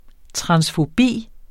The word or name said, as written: transfobi